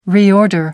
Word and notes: reorder: American English

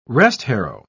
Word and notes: restharrow: British English

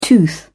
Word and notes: tooth: British English

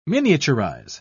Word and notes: miniaturize: British English

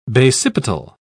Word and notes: basipetal: British English

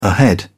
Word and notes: ahead: British English

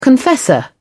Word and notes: confessor: British English

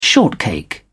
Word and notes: shortcake: British English

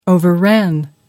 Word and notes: overran: American English

